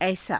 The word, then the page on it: aisaa: Hindi